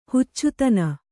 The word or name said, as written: ಹುಚ್ಚುತನ